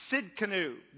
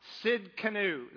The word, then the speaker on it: Tsidkenu: And this name is Jehovah Tsidkenu. The T is silent, okay, in Hebrew. Jehovah Tsidkenu.